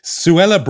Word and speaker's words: Suella: but I just have to come back to Suella Braverman. Suella rhyming with Cruella.